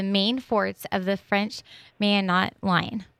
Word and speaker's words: Maginot: And that word is pronounced Maginot.